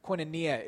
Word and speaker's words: koinonia: Talk about the role of community, about fellowship, about, in the Greek term, koinonia is how it's said, in that early church.